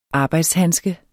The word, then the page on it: arbejdshandske: ˈɑːbɑjds-